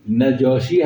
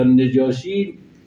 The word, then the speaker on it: نجاشی: سخن نجاشی رو خواستید نگاه کنید، رو تلفظ کلمه که نجاشی هست یا نجاشی، ضبطش ظاهرا نجاشیه، به کسر نون ها.